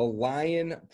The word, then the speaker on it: Lyon: He is the owner, and he runs the Lyon Property Management Group.